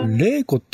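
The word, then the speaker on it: 祈子: レイコっていうのは、そこ、祈るに子と書いてから、はい、レイコなんですね。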